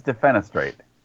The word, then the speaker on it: defenestrate: Is that how you pronounce it? I thought it was defenestrate. It is. Okay.